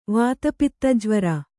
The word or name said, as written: ವಾತಪಿತ್ತಜ್ವರ